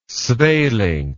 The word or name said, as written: sweelinck